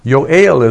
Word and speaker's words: Yoel: But it's Yoel is the original pronunciation.